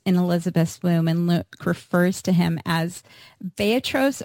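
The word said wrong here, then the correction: Brephos (βρέφος): or can you pronounce it for me? Brephos.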